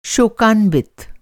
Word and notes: शोकान्वित: Hindi

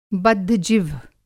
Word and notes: बद्धजिह्व: Hindi

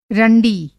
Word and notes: रंडी: رنڈی

